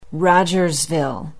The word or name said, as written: rogersville